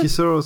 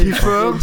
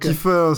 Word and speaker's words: Kiefer: Kisser Sutherland. – What? – C'est ça? – What? – Qu'est-ce que tu dis? Il essaie de parler. – Kiefer,